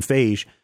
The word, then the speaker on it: Feige: Keep forgetting Feige. How to say his name. Feige. – I pronounce it just like beige.